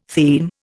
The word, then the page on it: theme: Indian